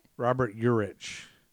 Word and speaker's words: Robert Urich: What did I say? Ulrich? – You said Ulrich. – How do you say it? – Robert Urich.